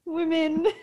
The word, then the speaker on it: women: Machine, spelled M-A-S-H-E-E-N. Daughter, spelled D-A-W-T-E-R. Mm-hmm. And my favorite, and the favorite of this guy's TikTok, is women, spelled W-I-M-M-I-N. Women. – Women. It's like if women was spelled like Jimin.